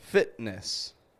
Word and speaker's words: fitness: Such as fitness. Here we have F-I-T-N-E-S-S. Fitness. We don't pronounce that T. We just stop it.